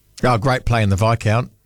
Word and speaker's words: Viscount: Was my first experience with the silent S was the Viscount.